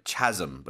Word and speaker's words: chasm: C-H-A-S-M. So it looks like chasm, but it's pronounced chasm.